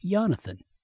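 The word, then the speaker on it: Yehonathan: His first name is spelled like Jonathan, but instead of the J is Y-E-H. He's on Facebook, so I emailed him about it, and he wrote back that it's pronounced Jonathan.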